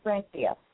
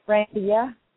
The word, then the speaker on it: Brandia: My name is Brandia. – I'm sorry. Say your name again. – It's Brandia. – Brandia. Okay, go ahead. – Yeah, it's like Brandy with an A, Brandia.